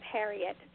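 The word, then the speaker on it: Herriot: I actually pronounce it Harriet, so some people might have heard it as James Harriet, but you may very well be right.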